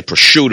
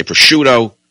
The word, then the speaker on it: prosciutto: You know, Italians say prosciutto. Americans say prosciutto.